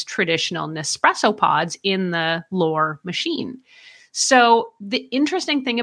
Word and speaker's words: L'Or: I'm from Canada, so this should be easy, but my French accent is terrible. It's L'Or. L'Or.